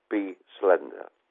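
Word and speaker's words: B-Slender: It's labelled capital B hyphen slender, pronounced Be Slender.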